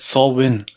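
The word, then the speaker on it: Samhain: Die Band heißt übrigens Sam Hain, der Feiertag heißt Saw Win.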